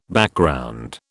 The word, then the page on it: Background: British English